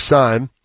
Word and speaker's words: Sime: Dave Sime. I believe I'm saying that right with the pronunciation. – Yeah, S I M E.